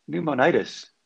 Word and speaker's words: pneumonitis: Pneumonitis? – Pneumonitis.